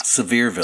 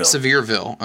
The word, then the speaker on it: Sevierville: It says it's from, and I'm going to mispronounce this, Sevierville, Tennessee? – Sevierville. – Sevierville, okay. – Yes.